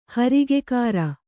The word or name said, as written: ಹರಿಗೆಕಾರ